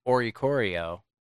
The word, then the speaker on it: Oricorio: Okay, I wonder what the snapshot surprise is going to be then, because if it is Oricorio, yeah, that's how it's pronounced. – Correct. – If it is Oricorio, which is going to be a new released Alolan Pokemon, then that's extremely exciting.